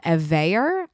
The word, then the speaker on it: Evaer: But I did find a tool that I love for Skype recording, and it's called Evayer. I don't even know if I'm saying it right ever. I've been using it for years. It's spelled E-V-A-E-R, and it's so reliable.